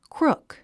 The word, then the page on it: crook: krúk; クルク